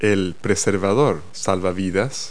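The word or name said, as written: life preserver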